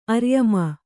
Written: ಅರ್ಯಮ

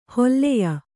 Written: ಹಲ್ಯ

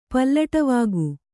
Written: ಪಲ್ಲಟವಾಗು

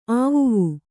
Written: ಆವುವು

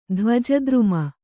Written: ಧ್ವಜದ್ರುಮ

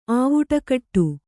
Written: ಆವುಟಕಟ್ಟು

